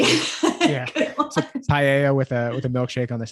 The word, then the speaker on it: Paella: And I went to Barcelona and I'm going to butcher the meal because now Pampaya? Is it Pampaya? The seafood meal? – I'm blanking on it too. Paella.